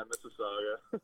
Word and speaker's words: Mississauga: Yeah, Mississauga.